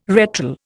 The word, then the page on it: retral: Indian